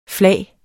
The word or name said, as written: flag